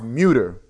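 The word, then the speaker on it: Mütter: It's pronounced Muter. – Muter Museum? – German Muter, I believe.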